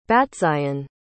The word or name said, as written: battzion